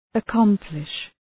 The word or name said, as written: accomplish